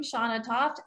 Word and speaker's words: Shanna Toft: You can type in my name, Shanna Toft, Shannatoft.